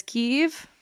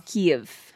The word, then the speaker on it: Kyiv: My total side question, I've always said Kiev, but it's Kiev. – Yes, the Russian pronunciation is Kiev, but the Ukrainian is Kiev.